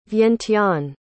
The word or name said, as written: Vientiane